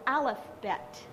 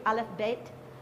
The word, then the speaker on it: Aleph Bet: So yesterday what we learned was an introduction to Hebrew, just the alphabet, and it's called an Aleph Bet in Hebrew. Aleph Bet. If you really wanted to pronounce it correctly.